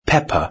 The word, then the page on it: pepper: British English